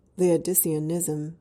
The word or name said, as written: Laodiceanism